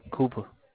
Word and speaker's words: Cooper: Cooper. – Spell that? – C O. – Yeah. – O P E R. Cooper.